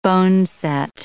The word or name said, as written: boneset